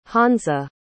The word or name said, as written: hansa